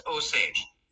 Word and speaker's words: Osage: And it is pronounced Osage.